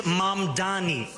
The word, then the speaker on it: Mamdani: And furthermore, the name is Mamdani, M-A-M-D-A-N-I. You should learn how to say it because we got to get it right.